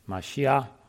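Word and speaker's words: Mashiach (מָשִׁיחַ): Verse 2 of Psalm 2 talks about how the Lord is setting up his Messiah, his anointed, Mashiach, is the way it's pronounced in Hebrew.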